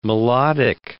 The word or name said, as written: melodic